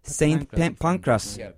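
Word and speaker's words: St Pancras: Konferans tam da Polanski'nin milletvekili adayı olduğu ara seçimden, Holborn ve St. Pancras ara seçiminden, eğer doğru telaffuz edebildiysem tabii, birkaç gün öncesine denk geldi. Öyle değil mi? – Evet. Evet, doğru.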